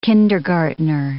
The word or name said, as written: kindergartener